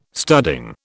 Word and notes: studding: British English

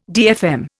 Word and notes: DFM: Indian

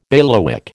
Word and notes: bailiwick: American English

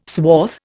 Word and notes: swath: Indian

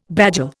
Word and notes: bagel: Indian

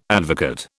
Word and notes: advocate: British English

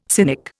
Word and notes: cynic: Indian

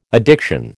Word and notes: addiction: American English

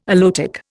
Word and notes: aloetic: Indian

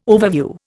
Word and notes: overview: Indian

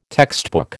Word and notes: textbook: American English

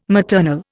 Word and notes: maternal: Indian

